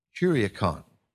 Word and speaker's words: kuriakon: That word was akin to an old German word that was spelled K-I-R-I-H-H-A, Kiraha. But both of those words derive from a late Greek word. Kuriakon, K-U-R-I-A-K-O-N, which comes from another Greek word, kuriakos, which is the possessive form of the word kurios, which is the term for Lord.